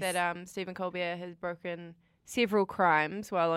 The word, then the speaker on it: Colbert: Colbert, yeah. It's Colbert, right? – Yeah. – It's not Colbert.